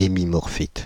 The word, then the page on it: hémimorphite: Paris; /e.mi.mɔʁ.fit/